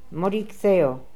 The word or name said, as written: Modiktheo